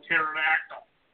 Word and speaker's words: pterodactyl: you don't pronounce the P in pterodactyl.